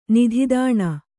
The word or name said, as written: ನಿಧಿದಾಣ